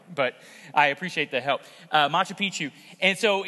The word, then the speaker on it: Machu Picchu: Did I say that right? Machu Picchu? Yeah, that's pretty close.